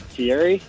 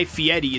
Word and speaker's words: Fieri: what is it, Thierry? – I mean, you're close. It is. – That's how it's spelled. You should take it. Congratulations, man. Hang on, we'll get some Foo Fighters to you. But Guy Fieri is how it's pronounced.